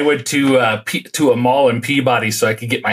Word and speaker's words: Peabody: It's Peabody.